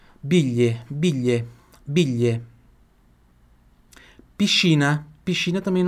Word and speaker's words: piscina: Em italiano, piscina, porque em italiano, i tem som de X.